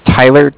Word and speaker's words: Tyler: My name is Tyler, T-Y-L-E-R. – Uh-huh. – And I'm calling with Cardiff. It's spelled C-A-R-D-I-F-F, as in Frank Frank.